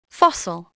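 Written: fossil

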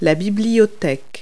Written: library

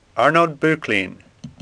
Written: Bocklin

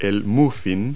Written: muffin

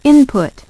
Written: input

